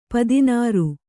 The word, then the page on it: ಪದನರಿ: padanari